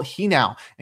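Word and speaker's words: Genao: Next, shortstop Angel Hinau. And yes, I looked it up. I pronounced it wrong yesterday, but Angel Hinau was recalled from the minors by the Guardians.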